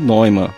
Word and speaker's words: Neumann: Voltando ao bug report dele, que então a pronúncia é Neumann. Nós erramos.